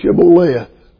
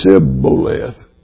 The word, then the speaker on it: Shibboleth: Well, you say Shibboleth. And they would say Sibboleth. They couldn't pronounce.